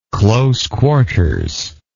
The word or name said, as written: close quarters